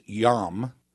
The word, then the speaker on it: yom: And I said, well, the Hebrew word for day is yom. We would spell it Y-O-M.